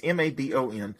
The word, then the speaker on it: Mabon: Which is autumn equinox, but it's also Mabon. And again, I'm trying to make sure these are ancient words, people. But it sounds, it looks like I'm pronouncing it correctly. It's Mabon.